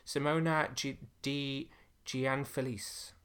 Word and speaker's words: Simona Di Gianfelice: Whoa, never going to be able to pronounce this. Simona Di Gianfelice. – That sounds about right.